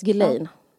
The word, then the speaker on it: Ghislaine: Vad jo, Jo, men det uttalas Ghislaine. – Ja. Okay. Det känns som jag är den enda i Sverige som liksom... Jag har aldrig pratat med någon om det, men i England säger man Ghislaine. Ghislaine mm. uttalas det. Om du har lyssnat på en podd från the BBC så säger de Ghislaine Maxwell.